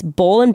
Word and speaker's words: Boll: That's Bull & Branch. The way it's spelled is B-O-L-L and branch, like a tree branch, .com.